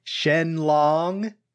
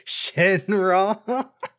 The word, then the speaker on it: Shenron: When they said Shenlong, I was what, like, do you mean Shenron? – That's how they spell it in the manga, Shenlong, but I think it's pronounced Shenron.